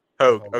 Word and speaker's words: Hoge: Is it Hoge? – Hogue.